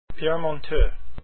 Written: monteux

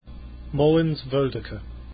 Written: Woldike

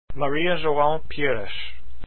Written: pires

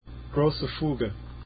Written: grosse fuge

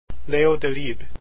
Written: delibes

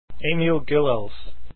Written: gilels

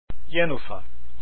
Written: jenufa

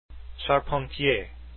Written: charpentier

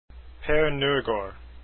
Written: norgard

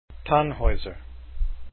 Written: Tannhaeuser

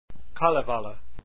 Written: kalevala

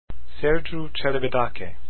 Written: celibidache

